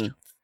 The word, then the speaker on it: Falun: Fuan Gong, is it? I can't remember how that's pronounced. – Falun. – Falun? – Well, I don't know how it's pronounced, but it's spelled F-A-L-U-N in English. Falun Gong.